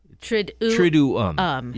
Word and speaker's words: Triduum: I said to you yesterday, just kind of, Triduum. No. – Triduum. – Yeah. – Yeah, there's two syllables there.